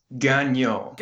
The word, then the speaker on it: Gagnon: Is it ga- ga- Gagnon? It's Gagnon. – Gagnon. Oh, just the last sort of little vowel sound. – Yeah, it was oh. super ah. close. Oh, okay. it's, it's passable. i accept it.